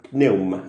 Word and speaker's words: pneuma: Y se pronunciaría así, pneuma, así, casi como, como, como tirando, tirando una salida, pneuma, así.